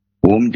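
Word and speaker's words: Om (ॐ): Om jo syllable hai. – Right, sir. – Teen akshar ka A, U, N, M.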